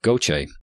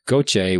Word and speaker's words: GOCE: But alas, no, the event was actually the uncontrolled reentry of Goche. And that is how you say it. I watched three different videos to hear it pronounced. Goche, which stands for the Gravity Field and Steady State Ocean Circulation Explorer.